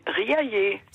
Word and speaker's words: Riaillé: Oui, Riaillé.